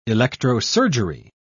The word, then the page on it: electrosurgery: British English